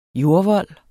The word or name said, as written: jordvold